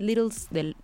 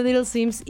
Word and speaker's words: Little Sims: Es Enough de Little Sims. Sí, de la semana. Sí, lo dije bien. Enough de Little Sims y Yukimi.